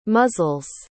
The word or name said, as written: muzzle's